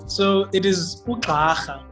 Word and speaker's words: Ugxarha: All right, so Ugxarha is spelled Gxarha.